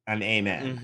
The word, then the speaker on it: Amen: This is Amen Thompson, and his name is spelled like, can I get an amen? – Mm-hmm. – But it's pronounced but it's Amen. – It's Amen, though.